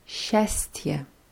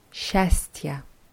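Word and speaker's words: Schästje (счастье): Glück heißt Schästje. – Schästje. – Ja, das Wort wird vorne mit S-C-A geschrieben. Die ganze Kombination wird aber als Scha ausgesprochen. Schästje.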